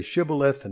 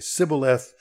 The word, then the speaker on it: Shibboleth: And we read on that in verse 6, and they would say to him, then say Shibboleth, and he would say Sibboleth, and he could not pronounce it right.